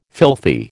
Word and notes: filthy: American English

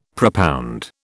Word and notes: propound: British English

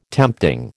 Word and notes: tempting: American English

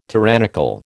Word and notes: tyrannical: American English

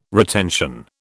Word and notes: retention: British English

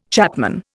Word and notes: chapman: Indian

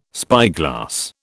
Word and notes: spyglass: British English

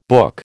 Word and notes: book: American English

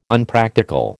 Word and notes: Unpractical: American English

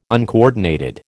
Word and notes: uncoordinated: American English

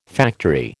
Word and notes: factory: American English

